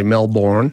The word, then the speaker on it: Melbourne: Although whether or not he pronounced the R is a bit doubtful. The pronunciation of the R in Melbourne. It would have been there originally, but it was on the way out in London English.